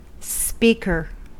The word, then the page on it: speaker: American English